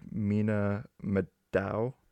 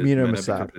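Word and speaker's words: Mena Massoud: I also am very white, but I believe it is Mina Masad.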